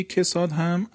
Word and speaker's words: کساد: کساد و راکد میشه. واژه کساد هم البته تلفظ دقیق و صحیح عربیش کساده، کساد